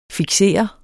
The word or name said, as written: fiksere